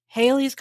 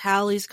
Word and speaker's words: Halley: Haley's Comet is actually pronounced Halley's Comet because it has two L's. It's it's it's pronounced like Valley Halley.